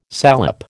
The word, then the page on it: salep: American English